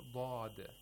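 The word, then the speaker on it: lād (ضاد): Actually, lād. They are lāds, and you have to pronounce it with istitāla. We have to have a lengthening of the sound of lād from the very back of the tongue to the tip of the tongue.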